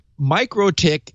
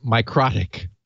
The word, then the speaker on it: MikroTik: Okay, so MikroTik is the way you're supposed to pronounce this. But I really did like pronouncing it Microtik because it sounds so awful.